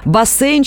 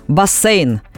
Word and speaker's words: бассейн: Да, потому что словари, адресованные работникам эфира, все-таки рекомендуют произносить «бассейн» с мягким «се».